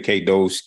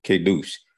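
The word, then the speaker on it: kadosh (קדוש): Some say kadosh, kadesh. In the Hebrew, that's spelled kuf, dalet shin, kadosh.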